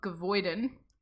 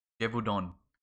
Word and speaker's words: Gévaudan: The Beast of Gavoiden. – Gavoiden. – Gavoiden. – That would, yeah, okay. That's how you would pronounce it if you were an English-speaking pleb. – Yes, and I am. – It's La Bête. De Gévaudan.